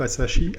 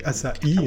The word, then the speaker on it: Asahi: Et depuis 2016, eh bien, elle, a été, euh, elle a été japonisée, puisqu'effectivement, c'est le groupe Asahi, Asahi hein, je pense qu'on prononce plutôt comme ça, qui, l'a récupérée.